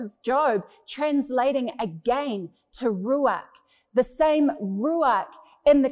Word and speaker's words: ruach: Pneuma in the Hebrew is a word ruach, or if you want to pronounce it properly, ruach.